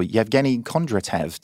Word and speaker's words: Yevgeny Kondratev: It is also a FinTech Insider debut for Yevgeny Kondratev.